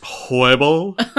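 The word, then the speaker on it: Huebel: And we had Rob Hubel as Harry, the station manager. – I believe it's pronounced Hoible.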